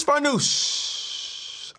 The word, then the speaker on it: Farnoosh: Listen, it's Farnoosh.